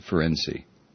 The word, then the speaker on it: Ferencz: I guess you pronounce it Ferenczi, F-E-R-E-N-C-Z.